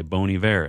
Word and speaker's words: Bon Iver: They say Bon Iver. And you're like, "Oh, that was non-obvious, but sure." And now they say that the reason why is because if you say it like that, it sounds like the French word for good winter.